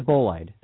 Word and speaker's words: bolide: It's a bolide. – That's how it's oh, pronounced. Oh, yeah, it's a yeah, bolide.